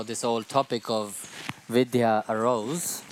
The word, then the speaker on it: Vidya: She writes V-I-D-Y-A. – Ona to píše V-I-D-Y-A. – But we pronounce it V-I-D-H-Y-A. – Ale my to... – Vidya, dha. – Pro ve skutečnosti vyslovujeme s tím dha, vidya.